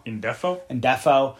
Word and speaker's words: Ndefo: Ndoko? No, Defco. – Ndefo. Casey Ndefo.